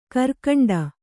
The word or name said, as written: ಗರಿಗಂಟಿ